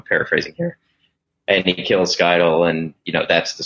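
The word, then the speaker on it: Scytale: If I'm remembering this right, it's been a few years since I've read it, but it's Skytel, the face dancer. Is that how you pronounce his name? – Skytel, yeah.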